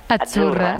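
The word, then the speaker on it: Azzurra: La Z no es la Z como decimos en catalán tan sonora, no. Es Achurra. – Muy bonito, muy bonito. – Es como pizza.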